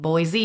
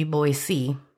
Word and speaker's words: Boise: The State House in Boise, which apparently, if you're saying Boise, you're saying it wrong. It should be Boise. – Oh, really? It should be Boise? – Yeah. – That sounds weird. – No Z, but an S.